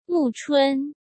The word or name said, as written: Mu Chun